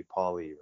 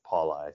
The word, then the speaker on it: Pauli: Yeah, I never correct anyone because, you know, phonetically it's supposed to be Pauli, right? I don't know what happened generations past, but for some reason we've always gone by Pauli.